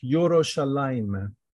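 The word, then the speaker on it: Yerushalayim (ירושלים): We pronounce it Yerushalayim.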